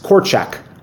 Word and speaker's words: Korczak: Yeah, I think he's been in previous World Cups, and I think the one was called Korczak. – Yes, Korczak would be the pronunciation.